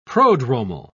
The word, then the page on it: prodromal: British English